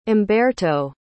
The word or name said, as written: umberto